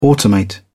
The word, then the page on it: automate: British English